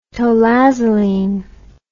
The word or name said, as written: tolazoline